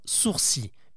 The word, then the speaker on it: sourcils: Mais je vous rassure, hein, on ne dit pas « sourcils », ça n'existe pas, même si ça pourrait faire sens. En fait, c'est plutôt le « l » qui pose problème. Tout le monde le prononce alors qu'on dit bel et bien « sourcils ».